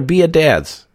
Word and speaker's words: Biadasz: The guy is from Wisconsin, and I still can never say his name right. Tyler Biadasz. Biadasz. Biadasz. It's Tyler Biadasz. Come on. Biadasz.